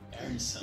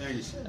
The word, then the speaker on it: Arneson: o Dave Arneson, né? Não sei como é que falar. Arneson. Isso, Arneson. É isso aí.